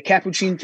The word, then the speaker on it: Capuchin: The Capuchin Kitchen, as I say that right, Smitty? – Yes. – Okay.